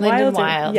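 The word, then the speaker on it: Linden Wyld: Linden Wild. Do you pronounce it Linden Wild? – Yes, yeah. Linden Wild.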